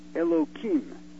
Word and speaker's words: Elohim: and there it says it uses the word Elohim. Now, Elohim, I'm saying Kim, it really should be Him, but I can't pronounce that.